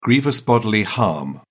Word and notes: grievous bodily harm: British English

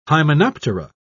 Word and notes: hymenoptera: British English

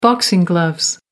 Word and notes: boxing gloves: American English

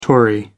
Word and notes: tory: American English

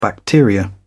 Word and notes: bacteria: British English

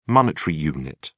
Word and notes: monetary unit: British English

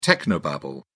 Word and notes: technobabble: British English

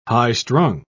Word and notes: high-strung: British English

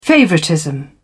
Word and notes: favoritism: British English

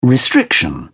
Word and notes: restriction: British English

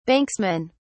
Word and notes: banksman: British English

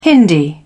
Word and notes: hindi: British English

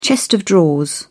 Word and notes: chest of drawers: British English

